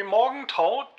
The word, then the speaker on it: Morgenthau: Na, Henry Morgenthau Jr. Morgenthau.